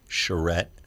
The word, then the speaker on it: Charette: Charette, C H A R E T T E.